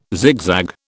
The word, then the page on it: zigzag: British English